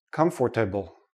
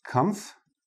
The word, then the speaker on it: comfortable: I've heard many students say, comfortable, comfortable. But native speakers, we don't say this part. We don't say the or. We say comfortable. And notice the last sound is like a small, tiny, tiny little I in there. Comfortable. Comfortable. Comfortable. Okay? We don't pronounce the or, comfortable.